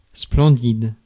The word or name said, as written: splendid